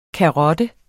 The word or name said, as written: karotte